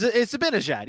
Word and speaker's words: Zibanejad: Mika Zibanejad? – Close. Very good. Oh, not bad. – Ding. – Mika Zibanejad, right? – It's Zibanejad, yeah.